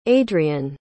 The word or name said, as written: adriane